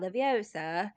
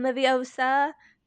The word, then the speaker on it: Leviosa: Another thing I did notice, though, as we're talking about Leviosa in the film, you do hear Rupert enunciate the O in Leviosa to call back her saying Leviosa, not Leviosa.